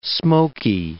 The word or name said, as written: smokey